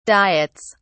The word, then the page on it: diets: British English